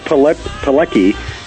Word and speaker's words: Pilecki: Pilecki, P-I-L-E-C-K-I. Looks like Pilecki, but it's pronounced Pilecki, and he's one of the chapters in Real Heroes.